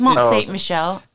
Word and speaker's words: Mont-Saint-Michel: Mont-Saint-Michel, how do you say it? – Mont-Saint-Michel.